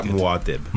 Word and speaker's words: Mwaib: It's more like Mwaib.